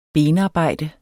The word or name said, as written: benarbejde